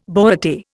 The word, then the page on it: borate: Indian